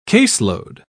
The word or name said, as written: caseload